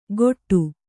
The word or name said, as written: ಗೊಟ್ಟು